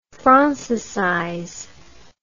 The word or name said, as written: francicize